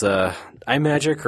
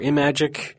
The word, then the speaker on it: Imagic: So another sort of publisher of Intellivision was iMagic or Imagic, because it's based on Imagine, and apparently we have listeners who get very upset when we do not pronounce that correctly. – So how do you say it? – Imagic. – Imagic. We were saying iMagic before.